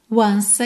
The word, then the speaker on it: once: Just to touch you once again. Once, Como o E final de once não é pronunciado, a gente já junta no again.